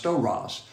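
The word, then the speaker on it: stauros: That's S-T-A-U-R-O-S, storos, pronounced storos.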